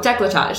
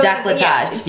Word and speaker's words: décolletage: Oh, décolletage. – So, décolletage.